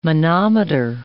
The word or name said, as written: manometer